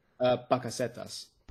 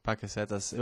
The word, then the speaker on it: Bakasetas: Yeah, I think your captain, I'm not sure how you pronounce his name. Can you say it again? – Bakasetas. – Bakasetas.